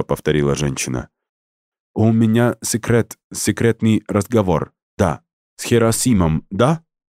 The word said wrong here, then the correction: Герасимом: Она сделала ударение на предпоследнем слоге, отчего имя информатика прозвучало как название японского города. – «Герасимом», — мрачно поправила Лида и скрестила на груди руки.